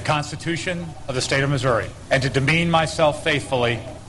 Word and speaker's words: Missouri: I say Missouri, which is to say I pronounce it correctly.